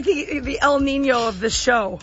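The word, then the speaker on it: Niño: So the little accent on top of the N makes it right. Nya.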